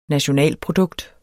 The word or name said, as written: nationalprodukt